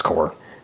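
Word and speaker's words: corps: That's pronounced core.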